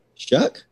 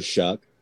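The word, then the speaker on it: Shough: I just wanted to throw it out there that You know, we got a grad transfer from Oregon, Tyler Shaw. Is it Shaw? I don't know. – Shuck? – That's how you pronounce that? Interesting. – Yeah, it doesn't look like that at all.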